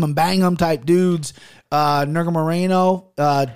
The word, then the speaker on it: Nurmagomedov: Nurmagomedov versus Kennedy and Zekchukwu.